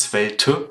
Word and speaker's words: Svelte: Und was du dir mal angucken kannst, wenn du mal neugierig bist bei Svelte, das wird übrigens Svelte geschrieben, aber Svelte ausgesprochen, nur falls ihr jetzt Svelte sucht und es nicht findet.